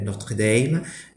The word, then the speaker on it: Notre-Dame: Là, j'ai été visiting professor à l'université de Notre-Dame. J'utilise la prononciation américaine, pas français, Notre-Dame.